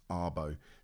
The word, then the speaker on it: Arbo: And I was like, how do you pronounce Arbo? So I looked it up and it's Arbo.